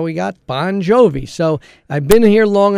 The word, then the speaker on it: Bon Jovi: And John Bon Jovi spells it B-O-N-J-O-V-I, but that is merely for phonetic reasons so that you can pronounce it properly. His correct spelling is the same as my spelling, B-O-N-G-I-O-V-I.